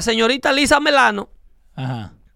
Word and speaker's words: Milano: En inglés se pronuncia melano.